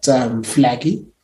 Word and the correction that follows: Flagey: Am I saying it right? Flagey. – I've got no idea. – Yeah, it's flage. It's like, it's very Frenchy.